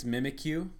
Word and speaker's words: Mimikyu: Do you know how to say this one without making I think it's Mimikyu. – Mimikyu? – Like, I'm going to mimic you. – Okay, I like that one. Mimikyu, I just want it to feel loved. – Mimikyu, I just want it to feel loved.